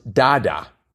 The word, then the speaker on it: Dada: Dada is pronounced Dada, okay? So the artist. Taibo, T-A-E-B-O, is a workout created by Billy Blanks and is a portmanteau of Taekwondo and boxing.